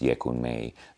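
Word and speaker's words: Oyeku Meji: So if you look at it on iTunes, it looks like 0000 plus 0000, and it's pronounced Yekun Mei.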